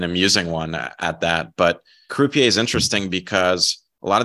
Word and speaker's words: Croupier: Is it Croupier? Is it French? Croupier. – That's right.